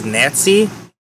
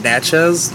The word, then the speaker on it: Natchez: So now, and Nats, almost, I keep pronouncing it as Nazi. It's called Natchez. Because when I first read it, in my head I was saying Nazi. And that's why I was Googling the pronunciation. I was like, oh, Natchez.